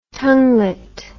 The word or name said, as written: tonguelet